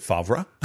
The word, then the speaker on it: Favre: Yes, but then you will also learn how to pronounce Carl Barth's name and Brett Favre's name. – Right, right. – Is it Favre? – It's Favre, yeah.